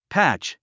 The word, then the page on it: patch: pǽtʃ; パァッチ